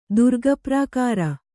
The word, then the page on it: ದುರ್ಗಪ್ರಾಕಾರ: Kannada